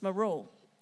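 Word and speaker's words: moro (μωρός): You know, the foolish word here used in the scripture in Matthew comes from the word moro. I didn't say it right this morning. It's moro.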